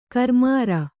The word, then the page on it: ಗರಿಮುರಿ: garimuri